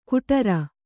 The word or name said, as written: ಗುಡಾರ